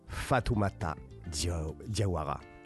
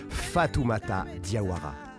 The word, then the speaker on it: Fatoumata Diawara: Fatoumata Diawara. Comment j'ai pu écorcher ce nom ? Je vais le redire. Fatoumata Diawara.